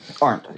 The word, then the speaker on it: Arndt: Is that how you say it? Linda mm-hmm. Arndt? – Arndt, yeah.